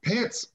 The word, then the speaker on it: Pence: I don't know how you say his name. – Pence. – Pence. Pence. That's what I thought I said, Pence.